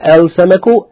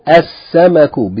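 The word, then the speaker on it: as-samaku (السَّمَكُ): The example, as-samaku, as-samaku, not al-samaku, as-samaku, because seen is from al-huruf al-shamsiyya, therefore the lam is not pronounced. It's assimilated or merged into the seen, which takes shadda, telling us to double it.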